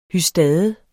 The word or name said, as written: hystade